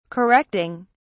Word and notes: correcting: British English

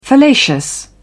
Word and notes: fallacious: British English

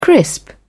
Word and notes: crisp: British English

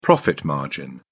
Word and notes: profit margin: British English

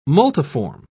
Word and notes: multiform: British English

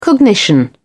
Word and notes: cognition: British English

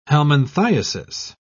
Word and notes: helminthiasis: British English

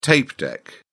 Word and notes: tape deck: British English

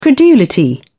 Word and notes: credulity: British English

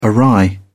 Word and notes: awry: British English

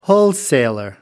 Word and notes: wholesaler: American English

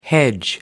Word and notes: hedge: American English

